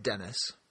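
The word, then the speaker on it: Denys: First of all, he has this information about Denny's Fisher, which is pronounced Dennis.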